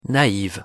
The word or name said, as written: naive